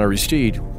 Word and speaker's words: Aristide: Jean-Bertrand Aristide, pronouncing it the American way, was the elected president of Haiti.